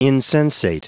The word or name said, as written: insensate